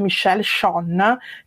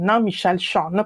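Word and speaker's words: Michel Chion: Valore aggiunto che la musica aggiunge alle immagini è proprio una frase, un'affermazione che dice Michel Schön, che è un grande teorico dell'audiovisivo, si scrive Chion, Michel Schön,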